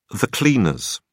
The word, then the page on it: cleaners: British English